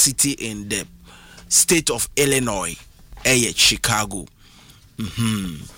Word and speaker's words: Illinois: Illinois the word is Illinois sn is silent